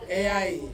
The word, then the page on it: ai: Ansus